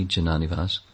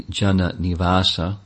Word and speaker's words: Jananivasa: Prabhupada named one of his disciples, the celebrated devotee, Jananivas. But it's actually Jananivasa, that's the correct pronunciation.